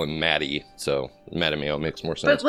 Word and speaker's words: Mattimeo: And this month we read the third book in the Redwall series in publication order, Mattimeo, which I have it on good authority. That's how it's supposed to be pronounced. I was saying it differently in my head, but apparently it's Mattimeo.